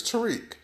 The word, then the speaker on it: Tarik: You see, my name is, like I said earlier, is Tariq. It's spelled T-A-R-I-K.